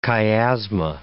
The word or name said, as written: chiasma